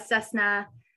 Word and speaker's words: Cessna: So I pronounced that right. I was worried I was going to mispronounce it. Correct. Cessna.